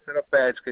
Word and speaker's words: Seropédica: Seropédica, isso aí.